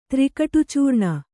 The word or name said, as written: ತ್ರಿಕಟುಚೂರ್ಣ